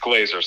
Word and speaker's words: Glaser: It's Glaser, spelled with an S, pronounced like a Z.